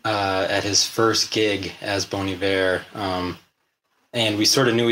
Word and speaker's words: Bon Iver: So he signed a letter to his friend, Good Winter, and just it looks like Bon Iver, but it's actually Bon Iver.